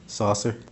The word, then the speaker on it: Saucer: Saucer. – How do you spell that? – S-A-U-C-E-R. – S-A-U-C-E-R? – Yes.